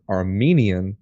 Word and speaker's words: Armenian: And for listeners out there, Arminian, like what we are, is spelled with an I. So it's A-R-M-I-N-I-A-N, right? Mm-hmm. That's Jacob Arminius. That's Arminian theology. The other one is spelled with the E, and that is Armenian, like the country, like the people group.